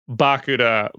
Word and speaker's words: Bakuda: It is pronounced Bakuda like Barracuda, and that's how I sing it in my head.